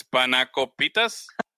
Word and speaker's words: spanakopita: Spanakopitas. Spanakopitas. Am I saying it right? Oh, spanacopitas.